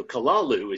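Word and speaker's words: Cthulhu: So Kalalu is how we're supposed to pronounce it, but like a speed metal band, something like Kalalu, something like that.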